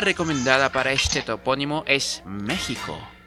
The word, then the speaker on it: México: Bueno, el caso es que su pronunciación correcta, México, no México.